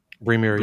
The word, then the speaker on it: Brymir: It's either Brymir or Bremir. – Bremir, Voices in the Sky.